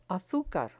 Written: azucar

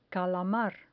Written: calamar

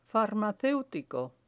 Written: farmaceutico

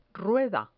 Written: rueda

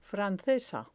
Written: francesa

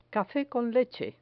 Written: cafe con leche

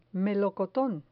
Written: melocoton

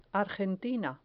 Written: argentina

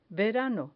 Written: verano

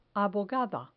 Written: abogada

